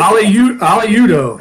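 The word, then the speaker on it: Udoh: They had plenty of penalties, and a lot of it seemed to center around someone you wrote about this week, right guard Uli Odu. Can't even see if that's the right way to say his name. – But Aliudo, yeah.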